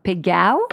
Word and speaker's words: Pegau: Yes, you have to pronounce Pegao. – Pegao. – If so you want to speak in thing, Provençal.